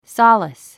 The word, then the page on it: solace: sɑ’ləs; ソゥレス